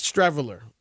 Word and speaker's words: Streveler: How do you say his name? Rob G? Streveler? Yes, Streveler will be the backup.